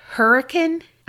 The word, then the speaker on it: Hurricane: One was a preschool in nearby Hurricane, I believe. She did the pronunciation, but it's spelled Hurricane. But she said, because Utah.